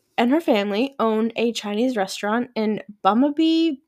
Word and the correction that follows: Burnaby: British Columbia. I don't know if I'm saying that right. Burnaby? – How do you spell it? – Burnaby. It has to be Burnaby. – B-U-R-N-A-B-Y. I think so.